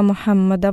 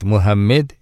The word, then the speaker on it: Мухаммад: Поэтому теологи ислама сказали, кто не может произнести имя пророка Мухаммада, чтобы не говорить Мухаммед или Мухаммед или Мамед, то произносит Абул-Касим или Абул-Гасим.